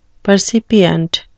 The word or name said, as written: percipient